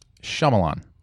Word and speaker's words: Shyamalan: When I sound out his name, Shyamalan. – Shyamalan. – Shyamalan. – Now say it normal. – Shyamalan. – Yeah. Are we saying it right? – Mm-hmm.